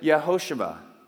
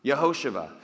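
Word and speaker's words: Yehoshua (יהושע): And you would pronounce it Yehoshua. Yehoshua.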